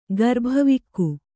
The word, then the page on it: ಗರ್ಭವಿಕ್ಕು: Kannada